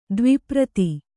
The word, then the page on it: ದ್ವಿಪ್ರತಿ: Kannada